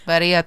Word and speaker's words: Birria: Birria taco. – Dude, and you're the white one. – I am. – Birria tacos.